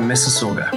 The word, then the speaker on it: Mississauga: It's the University of Toronto in Mississauga.